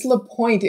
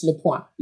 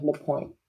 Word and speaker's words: LePoint: Is that how you say your last name, Olympia Lapointe, or is it like a silent T, Lapointe? – Ah, good question. It's Lapointe in English. In French, it's Lapointe. But I go by Olympia Lapointe.